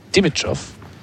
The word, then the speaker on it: Dimitrov: Dimitrov is pronounced with emphasis on the second syllable.